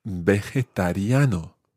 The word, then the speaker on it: vegetariano: And that phrase is, soy vegetariano. – Soy vegetariano. – Muy bien. It's quite tricky to say. Try that syllable by syllable. Ve. – Ve. – Je. – Je. – Ta. – Ta. – Riano.